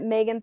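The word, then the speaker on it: Magen: You can find me on Instagram at Megan Thurman.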